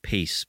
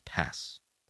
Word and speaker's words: Paz: It looks like pace, but it's pronounced pass.